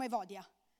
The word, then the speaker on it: Evodia: E poi però c'è l'altro nome, Evodia, c'è anche un altro modo di pronunciare, però la chiameremo Evodia.